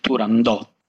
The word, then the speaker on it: Turandot: Io stesso sono nato e cresciuto con una pronuncia all'italiana, Turandot, e con la T finale nettamente pronunciata. Negli ultimi tempi c'è stata veramente una sorta di crociata da parte delle nuove generazioni contro chi pronuncia Turandot senza la T finale, quasi fosse, come dire, un retaggio di analfabetismo culturale, ecco.